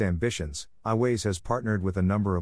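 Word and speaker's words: Aiways: iWays, pronounced iWays,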